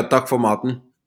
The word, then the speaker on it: takk for maten: So I'm going to say it incorrectly, but is it takk for mat? Is thank you for the food? – Yeah, takk for maten.